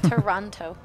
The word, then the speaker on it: Toronto: Toronto. – Yeah. – With the hard T. – Yeah. – Just take the T's out of that one. Orana. Orana. – I'm in Toronto.